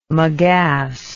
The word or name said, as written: megass